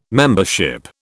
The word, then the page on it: membership: British English